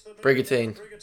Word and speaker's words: Brigantine: I don't actually know how to say that one. Let's have a look. Tell me how to say it. Sorry, it's going to tell me how to say it. Come on, Darren. Brigantine. That was hard, was it?